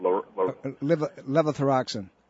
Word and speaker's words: levothyroxine: Levothyroxine. – Yes, levothyroxine. – Okay, so levothyroxine and synthroid, if you happen to be allergic to grain, you're going to be very sensitive to those two things. Levothyroxine and Synthroid are both derivatives from corn.